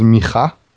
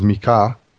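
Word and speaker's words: Micha (מיכה): So, in the Hebrew, we pronounce it as Micha. – Micha. – It might have been pronounced as Mika, because there is a discussion were there double-sounding consonants in Hebrew originally.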